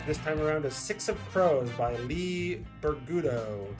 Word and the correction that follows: Bardugo: Did I say it right? Bardugo. I typed it wrong. That's why I said it wrong.